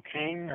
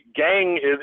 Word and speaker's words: Kang: And they do here have now, there was an article about 10 days ago where apparently the gang, I don't know why it's pronounced gang when it's spelled Kang, but gang...